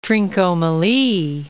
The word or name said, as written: Trincomalee